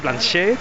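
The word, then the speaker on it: Blanchett: Pues con todos ustedes, la gran Kate Blanchet. – Pues con una pronunciación muy adecuada, porque es Blanchet, le dan paso a la primera Goya internacional de la historia que aparece fantástica en ese escenario.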